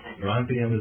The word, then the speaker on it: strontium: Is that how it's pronounced? – Strontium is how it's pronounced.